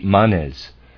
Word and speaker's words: Manes: I believe M-A-N-E-S is the plural. I'm going to pronounce that Manes.